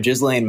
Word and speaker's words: Ghislaine: The one about, I never say her name right, Ghislaine Maxwell. – You know what I love? Chelsea Handler just calls her Jizz. – See, that's awesome. That's a good mnemonic device to remember it by.